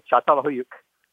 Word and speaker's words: Çatalhöyük: Okay, Çatalhöyük.